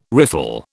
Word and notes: riffle: British English

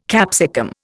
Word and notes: capsicum: Indian

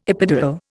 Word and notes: epidural: Indian

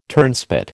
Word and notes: turnspit: American English

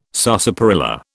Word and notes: sarsaparilla: British English

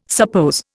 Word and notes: suppose: Indian

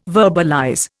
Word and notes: verbalize: Indian

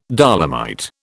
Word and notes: dolomite: British English

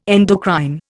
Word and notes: endocrine: Indian

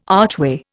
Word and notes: archway: Indian

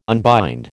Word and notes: unbind: American English

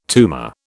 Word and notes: tumour: British English

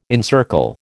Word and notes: encircle: American English

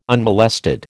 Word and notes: unmolested: American English

